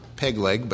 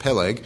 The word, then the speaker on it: Peleg: The name of one was Peleg," not Pegleg, but Peleg. "For in his day was the earth divided." Peleg means division.